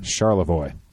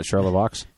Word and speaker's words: Charlevoix: Charlevoix. – Yes. That's the correct pronunciation. – Not Charlevoix? – Not Charlevoix.